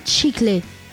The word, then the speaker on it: chicle: La sève de cet arbre est un latex appelé chicle, prononcé chiclé les Grecs utilisaient quant à eux le pistachier lentisque, qui est un arbre.